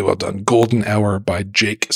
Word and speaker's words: JVKE: So there's a tune that everyone needs to Google because it's gorgeous, called "Golden Hour" by a guy named Jake, which is spelled J-V-K-E because 2023.